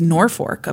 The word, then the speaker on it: Norfolk: And someone on Facebook corrected our pronunciation of something too, where like I had tried to. – Oh, Nor- you. I. It was uh, the place in Nebraska. It was Norfolk, apparently.